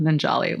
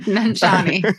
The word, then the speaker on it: Nanjiani: I don't want to get into body shaming, but have you seen Kumail Nanjiani lately? – He's ripped. I do not think you pronounce his last name correctly. – Nanjiani.